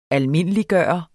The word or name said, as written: almindeliggøre